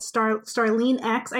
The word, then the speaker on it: Starline: And you can find me on Twitter at StarleneX. I have to be careful about how I pronounce my name. My name is pronounced Starlene, but for spelling purposes, it's Starline.